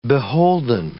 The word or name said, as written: beholden